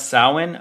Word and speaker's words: Samhain: We can talk about Halloween, Samhain, which is the pronunciation of that word is clearly satanic because it's spelled Samhain and they pronounce Samhain. What? Yeah, I that trips me out when you see that with the way it's spelled and you're like, there's no way it's pronounced that way. But it is.